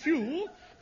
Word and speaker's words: Pugh: N-E-D-D-I-E. Neddy? What was next? – Neddy Pugh Seagull. – Pugh? P-H-E-W? – No. It's pronounced Pugh, but it's spelt Pug. – Pug, yes. P-U-G-H. – Yes. – There. Neddy Pug Seagoon. Wasn't it? – Yes, Seagoon. S-E-A-G-O-O-N.